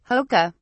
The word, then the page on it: hoka: British English